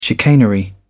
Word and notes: chicanery: British English

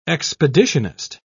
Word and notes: expeditionist: British English

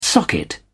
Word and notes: socket: British English

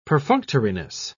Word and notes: perfunctoriness: British English

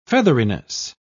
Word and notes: featheriness: British English

